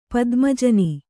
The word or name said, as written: ಪದ್ಮಜನಿ